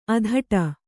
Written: ಅಧಟು

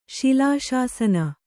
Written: ಶಿಲಾಶಾಸನ

